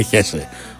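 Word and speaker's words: Hezze: Νομίζω, αν καταλαβαίνω καλά από του Αργεντινού, ότι η σωστή προφορά είναι Χέσε. Όπω καταλαβαίνετε, δεν μπορούμε να τον λέμε Χέσε. Οπότε εγώ θα τον λέω Χέτσε.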